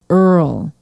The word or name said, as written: earl